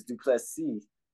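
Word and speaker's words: Du Plessis: And then the fact that in the second round, you seen Duplessis was, it's Duplessis, the S is silent.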